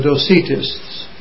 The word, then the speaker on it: Docetists: They were called the Docetists. D-O-C-E-T-I-S-T-S.